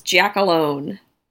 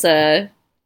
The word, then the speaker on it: Giacalone: I always pronounce it as Giacalone. But so no, okay, there you go. – If you want to get real Italian, I guess Giacalone.